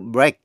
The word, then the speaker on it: break: Nhưng chữ EA phát âm là A trong chữ break. To break là bẻ gãy hay là làm bể. Windbreaker là áo bluzon mặc chắn gió. Nhưng khi là danh từ chỉ bữa ăn sáng, breakfast, thì EA phát âm là E như trong chữ bed. Quý vị nghe lại. To break, phát âm A. Danh từ breakfast, phát âm E. Break, breakfast. Break, breakfast.